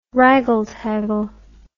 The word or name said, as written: wraggle taggle